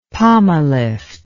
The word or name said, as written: pomalift